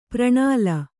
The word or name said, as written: ಪ್ರಣಾಲಿ